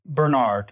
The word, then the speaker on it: Bernard: Is it Bernard? Is that the American, or Bernard? – Bernard, yeah. – Bernard, as in Jeffrey Bernard.